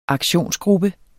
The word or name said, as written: aktionsgruppe